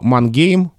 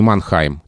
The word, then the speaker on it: Мангейм: Если говорить о немецких городах, то Мангейм не Манхайм, но при этом Хильдесхайм, а не Гильдесгейм.